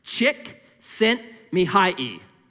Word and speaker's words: Csikszentmihalyi: Here's how it's pronounced. Csikszentmihalyi.